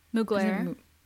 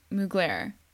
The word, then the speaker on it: Mugler: Mugler. – Mugler.